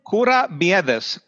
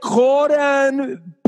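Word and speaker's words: kourabiedes: Let me phonetically work it out, because I want people to be able to see. That looks like corambithis. Corambithis, right? So that's what I said. And my wife's like, what the hell do you want corned beef for? We got ham here. And I knew I was pronouncing it wrong.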